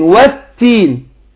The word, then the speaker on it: والتين: طيب يبقى اقول والتين، يبقى التاء هنا اقول والتين والتين، يبقى بينت التشديد بتاع التاء.